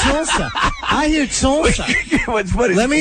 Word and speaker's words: Tulsa: You're both using two syllables, but he's going Tulsa. It's just like I'm gonna go out to my tool shed. I'm gonna go Tulsa.